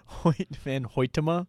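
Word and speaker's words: Hoyte van Hoytema: Hoyt van Hoytema for Best Cinematography.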